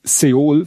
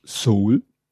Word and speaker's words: Seoul: Ja, dann habe ich Seoul falsch ausgesprochen, das heißt nämlich Seoul.